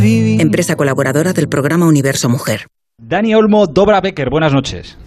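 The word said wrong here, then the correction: Dobra večer: ¿No lo he dicho bien? ¿Cómo es? – Dobra Becher.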